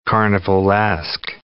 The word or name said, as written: carnivalesque